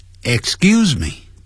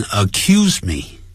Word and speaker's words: excuse: 首先我们来看 excuse me，这个我们已经教过了，不要念成 accuse me， accuse somebody 要指控某人呢、啊，那是 accuse， don't accuse me，不要呢指控我。